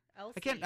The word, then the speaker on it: Elsie: Isla Fisher? Is that how you say her name? Elsa Fisher? – Elsie.